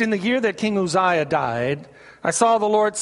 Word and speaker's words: Uzziah: In the year that King Uzziah died, and that's Uzziah. I know it's spelled with a U, but in the Hebrew it's a U, so it's Uzziah.